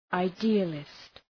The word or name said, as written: idealist